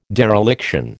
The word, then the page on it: dereliction: American English